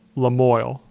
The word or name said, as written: LaMoille